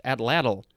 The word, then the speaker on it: atlatl: I think that is how it's pronounced, atlatl. Atlatl, that's what I want to say when I say that word. A-T-L-A-T-L.